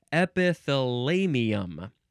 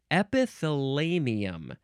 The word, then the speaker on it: epithalamium: Next is epithalamium. Epithalamium. E-P-I-T-H-A-L-A-M-I-U-M.